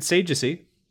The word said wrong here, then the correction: Sagacity: Oh, that said that wrong. Errant Sagacity.